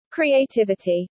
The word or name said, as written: creativity